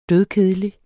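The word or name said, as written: dødkedelig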